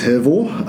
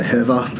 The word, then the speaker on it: Hervor: The actual correct pronunciation is Hervor. Um, a lot of people say Herva because that's, I guess, that's the Australian slang.